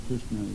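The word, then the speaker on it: Kṛṣṇā: So Draupadi is also Krishna. The feminine form of Krishna is the long A, I think, so it's Krishna.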